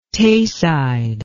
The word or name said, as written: Tayside